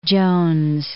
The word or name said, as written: Jones